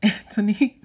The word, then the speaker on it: Antony: The love interest for Antony, which if you listen to my season 1 thoughts, you know, I hate saying Antony because it is spelled like Anthony, but whatever.